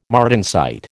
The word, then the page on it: martensite: American English